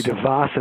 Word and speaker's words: DeVos: but what's her name, DeVoe? – Betsy DeVos, as it's pronounced,